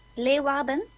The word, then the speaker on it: Leeuwarden: The first one's Leeuwarden, and the second one's Volkel.